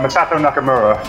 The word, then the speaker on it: Masato Nakamura: Masato Nakamura.